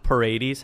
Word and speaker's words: Paredes: They added Isak Paredes. How is that pronunciation? – Yep.